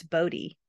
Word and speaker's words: Bode: It's spelled Bode, so I'm assuming that's Bodie, right? Wouldn't you say that that's Bodie? – Yeah, that sounds about right, yeah.